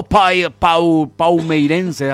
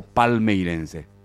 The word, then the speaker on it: palmeirense: Aló, paumeirense, así se pronuncia, ¿verdad? – Sí. – O palmeirense, o brasileiro, desculpa o meu português aí.